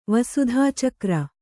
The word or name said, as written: ವಸುಧಾಚಕ್ರ